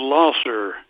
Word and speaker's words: Lahsar: We pronounce it Lasser. You know, it's Bohemian spelling or German spelling, L-A-H-S-A-R, Lasser. – Or in English, L-A-H-S-E-R.